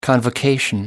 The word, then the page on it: convocation: American English